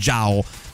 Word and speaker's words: Zhao: E mi ricordavo che si chiamava che contrariamente al fatto che si traslitterava con la Z, si pronunciava con una sorta di G, quindi Zhao.